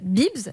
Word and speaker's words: Beebs: Je vous, je vous donne un exemple, ça s'appelle Bibs. Alors, ça s'écrit B-E-E-B-S.